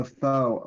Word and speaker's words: faux: But Michael Cohen says Trump hired a Fox Obama, that's a Fox Obama, so a Fox Obama, during White House run. This is Obama. – What was that? – A faux Obama. That's how that's pronounced.